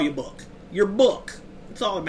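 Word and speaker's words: Bouc: I'm uh, so Poirot has that like super thick Belgium accent, and his name is spelled B O U C. I just call him Book because I don't know how else to pronounce it because everybody else calls him something different.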